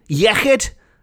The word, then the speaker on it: Iechyd: All of these wonderful sayings, when we raise our glasses in celebration, in Welsh, the equivalent is Iechyd Da, which is often pronounced Iechyd Da by people who haven't got their tongues around the correct pronunciation of the Welsh alphabet. But that is spelt I-E-C-H, or ch in Welsh, Y-D, Iechyd, and Da is D-A, Da.